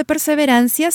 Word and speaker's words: perseverancia: Notice where the accent falls. Perseverancia. It's on the second to last syllable.